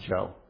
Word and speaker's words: Cho: But he refers to a gentleman by the name of Timothy Isaiah Joe, C-H-O, but I think it's supposed to be pronounced Joe, a graduate of Westminster Theological Seminary, Philadelphia, who says this: "The Bible is written from the lens of the marginalized.